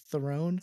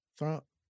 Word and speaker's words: Theron: I go by Theron because most people can't pronounce, it's actually pronounced Theron. – Throne.